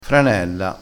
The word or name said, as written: franella